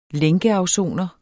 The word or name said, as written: lænkeafsoner